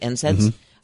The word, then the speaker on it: NSAIDs: And those NSAIDs, is that how you say it? NSAIDs? Mm-hmm.